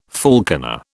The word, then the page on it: falconer: British English